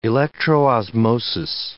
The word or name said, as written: electroosmosis